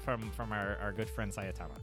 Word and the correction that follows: Saitama: I think I added a syllable in there. Anyway, cool. – Sayatama. With you, with the boy of the kelp to get the hair and you say Saitama.